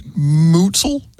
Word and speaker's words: Muetzel: Mutzel? – M-U-E-T-Z-E-L.